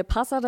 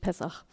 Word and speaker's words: Pessach: Also Pessach, wir sagen es in der christlichen Tradition, sagen wir Passa dazu, aber die jüdische, hebräische ähm, Aussprache ist eigentlich wirklich Pessach. Genau.